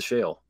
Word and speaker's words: Sheol: The Hebrew like Shul, place Sheol. Of the dead is Sheol. Shul, Sheol. It's like it's almost pronounced the exact same.